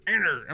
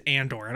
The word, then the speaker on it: Andor: Andor. I'm like, dude, it's just Andor.